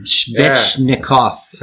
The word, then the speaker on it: Svechnikov: I don't know how to pronounce it, but Skvetsky, you know. What, Svechnikov? Svechnikov. Oh, Jesus, you butchered that one, I dude. butchered him. damn. Svechnikov.